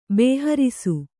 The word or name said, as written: ಬೋಹರಿಸು